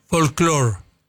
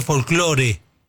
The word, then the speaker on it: folclore: Ah, porque lo pronunciaban sí, más o menos como el inglés. – Claro. – Folklore. – Folklore. – Y hace unos 30 años que se dice folclore.